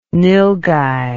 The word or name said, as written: nylghai